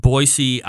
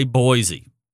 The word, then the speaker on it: Boise: and Boise, Idaho. Don't say Boise. They will laugh you oh. right out of the stands there, buddy. It's Boise, just like it's spelled.